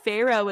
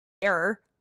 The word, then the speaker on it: Pharaoh: I thought Pharaoh was like fair for the longest time.